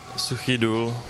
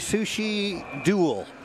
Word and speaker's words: Suchý Důl: Jiří Klíma, Suchý Důl, Česká republika. – So my pronunciation would be Suchý Důl. And that did not sound anything like that.